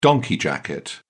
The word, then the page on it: donkey jacket: British English